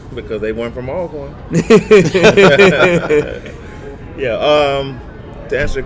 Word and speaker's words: Alcorn: It's Alcorn. It's not Alcorn. It's Alcorn.